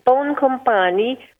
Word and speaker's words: Boncompagni: Yes, it's Boncompagni, which means good companion.